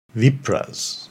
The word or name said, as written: Vipras